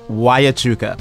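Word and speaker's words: Huachuca: Wyachuca. – Fort Wyachuca in Florence, Arizona, 70 miles outside of Tucson.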